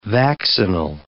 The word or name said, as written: vaccinal